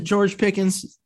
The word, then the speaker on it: George Pickens: And then, like you said, you could draft a guy that might be a little bit bigger, like a George Pickens from Georgia. Mm-hmm. Is it George Pickens? Did I say that right? – Yeah. – I want to say that's right.